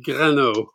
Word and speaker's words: Grano: Do you pronounce Grano? – Correct, how you said it. It's like Grano. Yeah, like Renault with a G at the front. Greno.